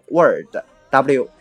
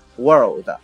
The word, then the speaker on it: world: 那么伊登啊在这里面再提醒一下大家，world 这个词的发音问题，有的同学呀经常读成 word，w o r d，就是我们说词啊。句子这样的含义，那就混淆了啊！这个世界这个词读作 world，中间呢有一个 r 的音，不要给忽略掉。